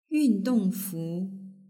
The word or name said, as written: yundongfu